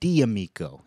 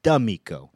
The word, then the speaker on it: D'Amico: D apostrophe Amico, pronounced D'Amico. You can tell somebody doesn't know what they're talking about because they pronounce it D'Amico. It's D'Amico, which is a weird name too because that means of friend, which is almost like a weird way of calling someone an acquaintance.